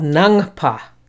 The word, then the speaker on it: Nangpa: The Tibetan word for Buddhist is called Nangpa. That's N-A-N-G-P-A, spelled phonetically in English.